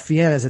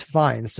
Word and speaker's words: Fiennes: But and then the last name is not Fiennes, it's Fiennes.